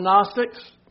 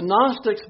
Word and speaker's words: Gnostics: Y'all remember the Gnostics? Remember, it starts with a G. The G is silent. The Gnostics believed the material body was nothing, has nothing to do with the spiritual body.